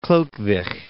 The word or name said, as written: Chlodwig